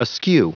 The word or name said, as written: askew